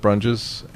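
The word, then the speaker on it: Brunjes: well, I'm on Flickr, and my name on Flickr is Joseph Brunges,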